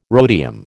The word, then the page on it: rhodium: American English